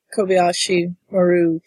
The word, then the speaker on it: Kobayashi Maru: So we're starting with Kirk's trial after he basically sabotaged the Kobayashi Maru test. Did mm-hmm. I say that right? I did that from memory. – Yeah. – You said it the way they said it in the movie anyway.